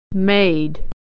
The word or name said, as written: made